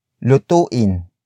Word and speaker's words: lutuin: For example, the words iluto and lutuin. Iluto, lutuin. Iluto, lutuin. Iluto is spelled as I-L-U-T-O, while lutuin is spelled as L-U-T-U-I-N.